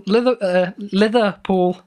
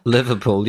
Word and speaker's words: Liverpool: Liverpool. Yeah, you struggle to say that, like you've never heard the word before. – That's how they pronounce it up there, isn't it? Litherpool. – You're pronouncing liver with like a TH instead of a V? – Yeah, lither.